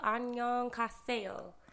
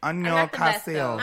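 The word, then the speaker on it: Annyeonghaseyo (안녕하세요): Annyeonghaseyo? No, kaseyo. Annyeonghaseyo. – Annyeonghaseyo.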